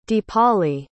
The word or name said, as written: deepali